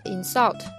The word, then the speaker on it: insult: 很多动词呢，如果它的动词、名词长得一样，那么它的发音重音部位会改变。比如说 insult，它的动词呢重音在这个 s u 上，啊，但它名词会在前边 insult。